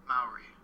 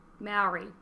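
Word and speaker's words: Māori: Maori. – Maori.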